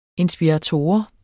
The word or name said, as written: inspirator